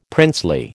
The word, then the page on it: princely: American English